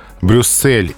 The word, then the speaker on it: Брюссель: Брюссель и Марсель произносятся именно так с твердым «с», а вот «Одесса» с мягким.